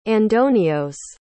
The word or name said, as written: andonios